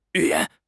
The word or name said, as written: 寓言